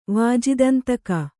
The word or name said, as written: ವಾಜಿದಂತಿಕೆ